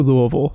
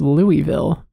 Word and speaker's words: Louisville: And actually, even on my flight to Louisville, someone was like, yeah, everyone thinks it's Louisville, but it's Louisville. Like, you really got to swallow that first syllable.